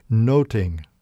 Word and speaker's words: nothing: In the final scene of McGibbon's King Lear, with the bodies strewn about, the word nothing resonates again and again as it's pronounced noting, noting.